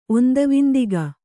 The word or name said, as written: ಒಂದವಿಂದಿಗ